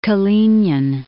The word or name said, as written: Kalinin